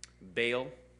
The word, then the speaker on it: Baal: Baal, it's spelled B-A-A-L, but it sounds like B-A-I-L, like in English, Baal. Like I'm out on Baal. I'm not, but you get to, let me keep going.